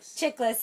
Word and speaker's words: Chiklis: And J-Lo, Jennifer Lopez, and Michael Chiklis. Is that how you say his name? – Chiklis.